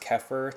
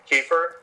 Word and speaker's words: kefir: And I did buy some, I think it's pronounced kefir today. Have you heard of that? – Kefir? – Kefir, is that how you pronounce it? – Yeah.